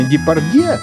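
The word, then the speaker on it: Депардьё: Ну, в средствах массовой информации чаще встречается написание Депардье, которое допускает двойное произношение конечной гласной. Кстати, вот в российском паспорте буква Ё, фамилия актера, присутствует.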